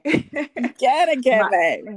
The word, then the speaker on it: Ginigeme: Ginigeme.